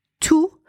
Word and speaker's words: tous: You pronounce T-O-U-S tous when there is no noun after it. For example, ils sont tous partis. You pronounce T-O-U-S tout when there is a noun after it. Par exemple, tous les enfants vont à l'école.